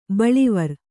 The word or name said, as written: ಬಳ್ಳಿವರಿ